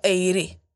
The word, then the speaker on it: Aire: Vi uttalar det precis som hon har skrivit. A-I-R-E. Alltså Eiri.